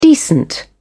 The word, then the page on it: decent: British English